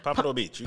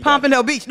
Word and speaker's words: Pompano Beach: This is All Purpose Radio, live from Pompano Pump. I've been saying it right all day. – Pompano Beach. – Pompano Beach.